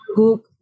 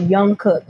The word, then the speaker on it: Jungkook: he didn't pronounce the J. He just said Young Cook. And I'm like, how do you look at this name and say Young Cook?